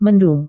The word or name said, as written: mendung